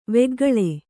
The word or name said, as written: ವೆಗ್ಗಳ